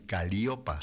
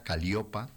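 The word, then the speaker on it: Calíope: A Caliopa, con acento en la I, Caliopa, Santa Caliopa.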